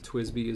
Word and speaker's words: TWSBI: Twisby is one that comes to mind. T W S B I is how it's spelled. Um, is that your favorite pen?